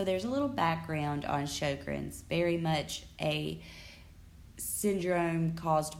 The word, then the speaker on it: Sjögren's: Now, Sjogren's is spelled S-J-O-G-R-E-N-S. And it's Sjögren's syndrome.